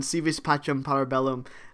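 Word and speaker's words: Si Vis Pacem, Para Bellum: Civis Pacem Parabellum.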